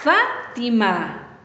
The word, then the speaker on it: Fátima: Fátima. ¿Dónde escuchas la fuerza de voz? Fátima. Muy bien, en fa.